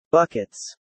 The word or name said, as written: buckets